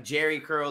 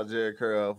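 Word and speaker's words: Jheri: I didn't know that this whole time it wasn't like Jerry curl, like Tom and Jerry. It's J H E R I. – Yes. – Yeah, the H is silent.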